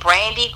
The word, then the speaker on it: Brandi: My Facebook is, I'm going to spell it, it's my name, Brandi Grimaldi. It's B-R-A-N-D-I. That's Brandi with an I, not a Y. Mm-hmm. And Grimaldi is G-R-I-M-A-L-D-I.